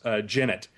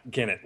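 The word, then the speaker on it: genet: So this is quite surprising, and I guess it's pronounced Jennet, and not Gennet, like I said, a Jennet.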